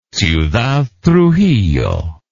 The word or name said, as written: ciudad trujillo